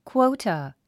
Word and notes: quota: kwóutə; クォウタ

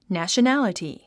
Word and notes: nationality: næ`ʃənǽləti; ナショナラティ

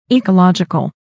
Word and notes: ecological: ìːkəlάdʒikəl; イーカラジカル

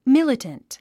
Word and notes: militant: mílət ə nt; ミラタント

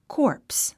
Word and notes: corpse: kɔ́ːrps; コープス

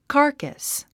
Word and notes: carcass: kɑ’ː r kəs; カーカス

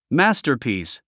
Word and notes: masterpiece: mǽstə r pìːs; マスタァピース

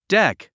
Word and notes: deck: dék; デク

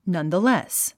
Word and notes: nonetheless: nʌ̀nðəlés; ナンザレス

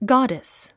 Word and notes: goddess: ɡάdis; ガァデス

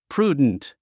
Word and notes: prudent: prúːdnt; プルゥデント

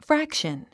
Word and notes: fraction: frǽkʃən; フラクション

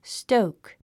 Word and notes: stoke: stóuk; ストウク

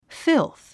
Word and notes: filth: fílθ; フィゥス

